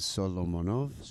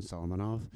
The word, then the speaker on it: Solomonov: Which is the correct pronunciation? – The correct pronunciation is Solomonov. But nobody gets that. So I've accepted the fact, and this is sort of like who I am a little bit. The American pronunciation, Solomonov. – Yeah. – Or Solomonov is fine.